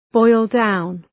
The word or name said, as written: boil down